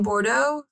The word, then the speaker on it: Bordeaux: Mr. Brisbane says that he has seen four little girls playing light as a feather, stiff as a board in Bordeaux, France.